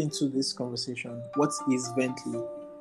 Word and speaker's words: Vently: So today we're going to learn all we can about Vently.